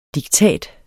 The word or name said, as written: diktat